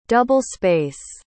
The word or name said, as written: double-space